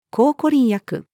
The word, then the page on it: 抗コリン薬: female